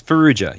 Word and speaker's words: Farrugia: You're close. Farrugia. You're just Farrugia. About there.